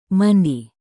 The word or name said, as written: mandi